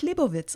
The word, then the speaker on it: Leibovitz: Ich habe gelernt, sie spricht sich Leibovitz aus.